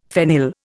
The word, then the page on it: phenyl: Indian